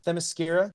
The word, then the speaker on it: Themyscira: Themyscira, filled with political intrigue and scheming between power players.